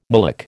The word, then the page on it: mullock: American English